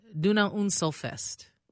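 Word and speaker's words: Donauinselfest: I looked and listened on two websites, and I hope this is right. But it's Duna Unselfest.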